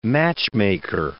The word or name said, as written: matchmaker